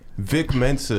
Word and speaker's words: Vic Mensa: Wasn't Vink Mensa heavy? How you say it? – Vic Mensa.